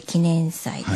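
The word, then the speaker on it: 祈年祭: 今日はですね、はい、記念祭について伺っていきたいんですけども、はい、祈る年祭りと書いて記念祭ですね、はいはいはい、これはどういうお祭りなんですか。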